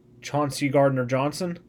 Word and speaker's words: Chauncey Gardner-Johnson: Chauncey Gardner-Johnson.